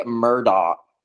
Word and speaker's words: Murdaugh: Really, in my head this entire time, it's been the Murdaugh because there's no K, but, you know. – The G-H is silent, like lasagna.